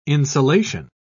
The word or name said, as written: insulation